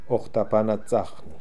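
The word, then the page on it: Okhtapanatsakhnee: Russian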